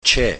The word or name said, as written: Che